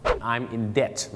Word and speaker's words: debt: debt นะครับไม่ได้ออกเสียงว่า debt แล้วก็ไม่ได้ออกเสียงว่า debt ด้วยนะฮะบางคนบอก debt นะฮะเขาออกเสียงว่า debt นั่นเองครับตัว b จะข้ามไปเลยนะครับเขาออกเสียงว่า debt อย่างเช่นถ้าตอนนี้ผมเป็นหนี้เนี่ยนะครับ I'm in debt